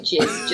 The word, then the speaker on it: GISS: So it's jizz spelt G-I-S-S. Just people pronounce it jizz just to be hilarious because everybody thinks it's funny. So it's actually giss or jizz.